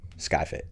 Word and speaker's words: SkyFit: That was honestly the inspiration, and there's more to it, but the biggest thing was people didn't know how to pronounce SkyFit. One of my very good friends I went to college with, who I was doing some coaching with, and he, like 6 months into our coaching, just casually said, "Oh yeah, like Shoey Fit." I was like, "Is that a joke?" And he was legitimately, he thought that it was pronounced Shoey Fit.